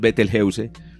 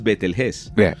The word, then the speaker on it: Betelgeuse: No se pronuncia Betelges, exacto. Así como, eh, así como lo decía tu, ah. – estudiante. Él decía, lo más cercano a la fonética persa o, árabe es Betelges. ya mira,